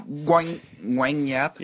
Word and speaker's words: Guang Yap: G-U-A-N-G. – G-U-A-N-G-Y-A-P. Oh, Gwang Gap. – Is it Gwang? – I don't know. I don't know why I just said Gwang that like Yap. I knew what I was talking about. Yeah, it's Gwang Gap. Yeah. – Gwang Yap? – Yeah, we're good.